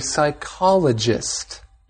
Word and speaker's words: psychologist: And if you studied this at a university or somewhere, maybe you want to go on to become a psychologist. P-S-Y-C-H-O-L-O-G-I-S-T, a psychologist. – Yes, it's a very interesting word. The P is silent. You don't make a P sound at the beginning of this word, just psychologist.